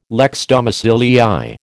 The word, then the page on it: lex domicilii: American English